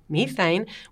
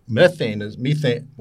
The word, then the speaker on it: methane: Methane, we Americans say methane, sorry.